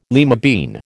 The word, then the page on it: lima bean: American English